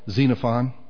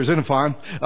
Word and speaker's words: Xenophon: There was another man called Xenophon. Starts with an X. You pronounce it Xenophon or Xenophon.